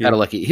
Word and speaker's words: Padalecki: How do you say his name? Padalecki? – Padalecki.